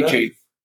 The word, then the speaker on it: Achane: How do you say A-chain.